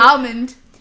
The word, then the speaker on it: almond: Almond. – But see, you really, you put a heavy emphasis on the L. The L should be a little bit lighter. – Is it almond? – Almond.